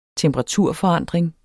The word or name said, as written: temperaturforandring